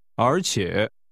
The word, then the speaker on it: 而且: 而且 is pronounced with a rising and a falling rising tone.